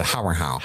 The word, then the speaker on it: Harrenhal: Yeah, as they pronounce it, Harrenhal. – Harrenhal. It's like this ZZ Top song.